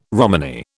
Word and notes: Romany: British English

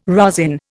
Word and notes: rosin: Indian

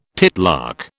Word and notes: titlark: British English

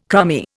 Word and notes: crummy: Indian

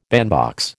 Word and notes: bandbox: American English

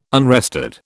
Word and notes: unrestored: British English

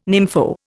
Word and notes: nympho: Indian

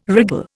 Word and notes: wriggle: Indian

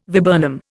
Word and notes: viburnum: Indian

